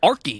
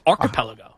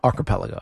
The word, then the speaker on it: archipelago: He's an archipelago. – No, it's not an archy. It's archipelago. – Archipelago. – Archipelago. – He's just emphasizing the wrong archie? Syllable.